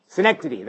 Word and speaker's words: synecdoche: I went to Google to make sure I had the right pronunciation, but Synagogue or Synagogue. Synecdoche, that's it.